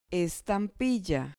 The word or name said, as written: estampilla